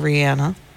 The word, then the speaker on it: Rihanna: Rihanna, or Rihanna, which is the technically correct way to pronounce it, and Lady Gaga coming up only on 100.5 of The Party.